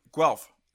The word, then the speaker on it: Guelph: And you're from Guelph.